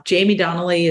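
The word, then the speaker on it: Jaime Donally: But Jamie Donnelly is the name, is what I'm labeled as in all my social channels.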